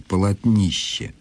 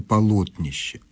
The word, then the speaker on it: полотнище: Как правильно произносить слово «полотнище»? С таким ударением или с ударением на третьем слоге «полотнище»? Дело в том, что Людмила Афанасьевна усомнилась после того, как на Первом канале телевидения в одной из программ ведущей это слово было произнесено с ударением на третьем слоге. Я думаю, что это была просто оговорка, правильное ударение «полотнище».